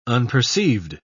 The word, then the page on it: unperceived: British English